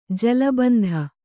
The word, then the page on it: ಜಲಬಂಧ: jala bandha